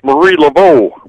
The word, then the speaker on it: Marie Laveau: That's Marie Laveau. – Laveau. See, I didn't know. Didn't have a pronouncer with it, so I don't know. – That's a French.